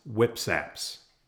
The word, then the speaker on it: WPSAPS: A new tool recently introduced to the NPS is a computer application called Wildfire Prevention Spatial Assessment and Planning Strategies, WPSAPS, pronounced WIPSAPS.